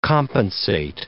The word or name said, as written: compensate